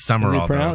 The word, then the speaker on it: Sumrall: Is that how you say it? He's new. Sumrall. – I know it's weird. It is Summerall. – Is it pronounced Summerall? – Yeah. – I feel like there's a letter missing. – It is. It's not spelled right, but that's how it's pronounced.